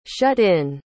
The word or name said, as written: shut-in